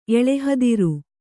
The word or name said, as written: ಎಳೆಹದಿರು